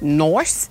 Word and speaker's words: Nice: It's actually pronounced nice. – Nice? – Nice. – Nice. – N-I-C-E, nice.